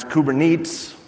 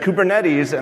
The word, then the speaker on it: Kubernetes: That's Orca with a K, and it stands for Orchestration with Kubernetes, which I was going to pronounce Kubernetes. And I checked, and they said Kubernetes, and I thought, you know what, I should have just pronounced it wrong. It would have been more on brand.